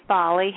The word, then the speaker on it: Folly: Hey, my name's Folly. How are y'all? – Great. You're going to have to spell that name for me. Folly? – F-O-L-L-Y, just like it sounds.